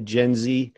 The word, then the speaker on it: Jenzy: It's spelled J-E-N-Z-Y, Gen Z.